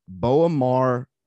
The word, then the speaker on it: B'omarr: But the name of this thing, and I might pronounce it incorrectly, is Boamar or B'omarr Monk. – Yeah, that's it.